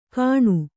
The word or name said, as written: ಕಣು